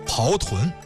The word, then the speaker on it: 刨豚: 那给您说这么一道，它叫做刨豚。刨啊，就是刨落之行那个刨，或者说它就是大炮的炮，这是一多音字。豚呢，就是海豚的豚。